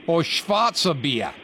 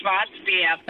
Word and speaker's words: Schwarzbier: Or Schwarzer Bier? – It's going to be Schwarzbier.